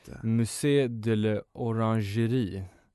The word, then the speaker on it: Musée de l'Orangerie: Jag tänker också på eh, Orangeriet i Paris, eller Musée de l'Orangerie.